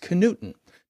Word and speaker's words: Knudten: And my last name is pronounced Knudten.